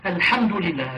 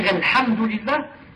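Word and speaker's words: الحمد لله: Vous remarquez par exemple, les reconvertis ou celles qui ne parlent que le français, par exemple, ont des difficultés, par exemple, de prononcer le H. Le H en disant Alhamdulillah, Al-Ha. C'est difficile. Ils prononcent, par exemple, Ha. Ils disent Alhamdulillah, par exemple, comme ça.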